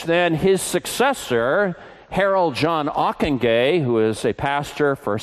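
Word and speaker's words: Ockenga: and whose name everyone doesn't seem to know how to pronounce, it's Ockenguy.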